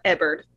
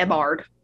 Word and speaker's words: Ebbard: And Ebbard, not Ebbard, which is fine because it is spelled Ebbard. But Ebbard is actually just the word bard.